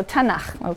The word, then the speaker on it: Tanakh: Tanakh, which is really the letters T, N, and Ch. And they put little A's in there to make it easy to pronounce, because T is hard to pronounce.